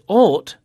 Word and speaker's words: ought: Now, the words thought, spelt T-H-O-U-G-H-T, and ought, spelt O-U-G-H-T, both have the O-U-G-H pattern in them, and it's pronounced all. Thought. Ought. – Thought. Ought. – Mm. – And there are more O-U-G-H verbs with the same pronunciation, like fought, the past of fight, and bought, the past of buy.